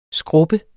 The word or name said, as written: SKRUBBES